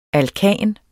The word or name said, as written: alkan